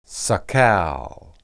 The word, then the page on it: sakal: Hebrew